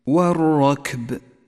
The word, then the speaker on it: والركب: اقرأ كلمة والركب ولاحظ أن اللام شمسية تكتب ولا تلفظ ولذلك ميزها لك مصحف التجويد باللون الرمادي إشارة منه إلى عدم نطقها.